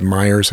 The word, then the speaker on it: Meijer: Well, one of the guys that was stood out to me is this, I think it's Myers, how you pronounce it. They run his family is the, it's spelled M-E-I-J-E-R.